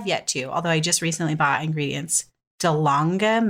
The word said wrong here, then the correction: Dalgona: Is that how you say it? – Oh, my God. Wait, you're just making me realize something. I think it's Dalgona. – Dalgona. – It's South Korean, yes.